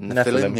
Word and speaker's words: Nephilim: I think that's how you say it. Nephilim? – Nephilim, yeah.